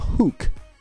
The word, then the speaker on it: hook: 哎，那在之前有一句话用了一个表达叫 hooked on，你应该有注意到，这个也是一个很地道、非常口语化的表达上瘾的方式。但是这个词不读作 hook，而是读作 hook，hook，跟我之前讲的 look 一样的道理，不读作 look，这个是一样的道理，hook，千万不要再读错了。